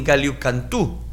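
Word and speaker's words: Gallicantu: En Israel está la iglesia de San Pedro en Galiucantú, así se pronuncia.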